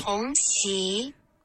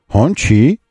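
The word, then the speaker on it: Hongqi: Не хонцы, а вот так еще раз. – Хонси. – Хонси. Понятно вам? Вот, но мы же не настоящие сварщики, и поэтому мы произносим так, как, ну, так, как произносится у нас, как мы, как у нас это принято. Как у нас это принято. А у нас принято это произносить хончи.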